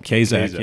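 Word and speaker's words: Kaszuk: Kazak, yes.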